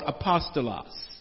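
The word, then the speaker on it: apostolos (ἀπόστολος): In the Greek, it is pronounced or called apostolos.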